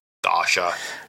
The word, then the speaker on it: Dacia: We'll start towards the top and go with Dacia, or Dacia, however we're supposed to pronounce that. – Dacia.